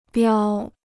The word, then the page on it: 标: biāo